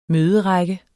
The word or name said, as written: møderække